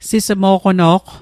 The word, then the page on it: sismo'qono'q: Mi'gmaq